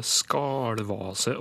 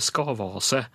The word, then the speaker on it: skavase: Nå er det noen uttalevarianter her også, som hun også nevner. Da, både skalvase og skarvase, i tillegg til, til skavase.